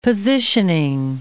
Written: positioning

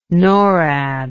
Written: norad

